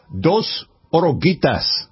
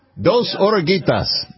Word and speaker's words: Dos Oruguitas: It is Dos Oruguitas. Did I say it right? – Yes. – Dos Oruguitas.